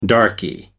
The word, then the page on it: darky: American English